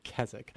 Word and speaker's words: Keswick: That's spelled K-E-S-W-I-C, and the W silent, so it's Keswick.